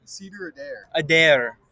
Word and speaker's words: Cedar Adair: Cedar Adair.